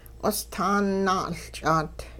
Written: q'ostaan naa'uLch'aat

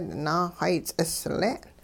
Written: naaxay ts'isle'n

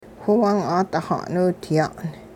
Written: whiwung-'aadixa'niwdya'n

